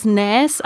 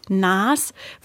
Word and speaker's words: Nas: Ich dachte bisher immer, man spricht es Nas aus, aber unsere schlaue Aussprachendatenbank, die ich hier zur Verfügung habe, die sagt, man sagt tatsächlich Nas.